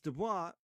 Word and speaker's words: Du Bois: And I put up, just for those of you who are like, it's Du Bois. I have what he says it is in his own letter. So I put it up on the video of how he wants it pronounced. He wants the oi to be boy, as in boy.